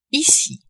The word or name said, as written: ici